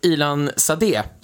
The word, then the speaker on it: Ilan Sadé: Men jag tänkte fråga, du hade ju en intervju / var det debatt, jag vet inte riktigt, jag kunde inte höra riktigt, med Ilan Sadé, så uttalar man det, eller hur? – Det stämmer.